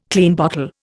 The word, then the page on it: Klein bottle: Indian